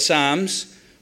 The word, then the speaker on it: psalms: We've got a book, the longest book, 150 of these things called psalms or palms, if you want to say it wrongly or rightly.